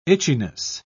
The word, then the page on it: itchiness: British English